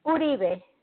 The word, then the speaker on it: Uribe: Did I say that right? Uribe? – It's Uribe. Uribe or Uribe.